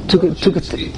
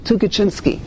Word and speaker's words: Tukachinsky: Tukachinsky. – I can't even pronounce his name. Tukachinsky.